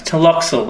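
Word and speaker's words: Tlotoxl: Taloxel. That's right. It starts with a T.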